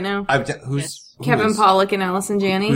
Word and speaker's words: Allison Janney: And next week, they're interviewing, I'm not going to say her name right, Allison Janney? – Yep, yes. Allison Janney.